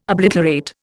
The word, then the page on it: obliterate: Indian